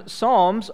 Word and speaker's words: psalm: Psalm, for some of you who are doing some spelling at school, psalm is spelled with a P. P-S-A-L-M. That sounds funny, doesn't it? But we say it psalm.